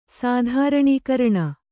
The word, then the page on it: ಸಾಧಾರಣೀಕರಣ: sādhāraṇikaraṇa